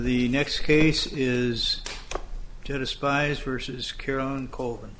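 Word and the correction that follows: Jutta Spies: Dana Duncan, on behalf of the plaintiff, it's pronounced Uta Spies. She's a German immigrant.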